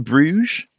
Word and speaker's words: Bruges: Dan Griffin, at DanGriffin21 on Twitter, he says, my favourite movie is In Bruges.